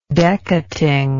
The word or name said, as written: decating